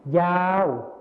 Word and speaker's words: ยาว: ยาวอีกครั้งนะครับยาวในภาษาถิ่นอีสานนะครับจะออกเสียงเป็นเหย้าเหย้า